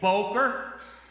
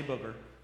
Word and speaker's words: boker: Say boker. Don't say booger. Say boker.